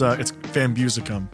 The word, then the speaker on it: VanBeusekom: Yeah, so it's VanBeusekom.